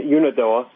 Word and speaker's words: Unadilla: At Unadilla.